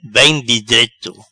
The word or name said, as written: Bem di dreto